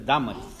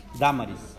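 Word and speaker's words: Dámaris: Damaris, no es Damaris. Acento en la A. – Damaris.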